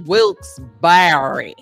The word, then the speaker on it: Wilkes-Barre: Wilkes-Barre. It's pronounced Wilkes-Barre. – Yeah, it's Wilkes-Barre. Wilkes-Barre.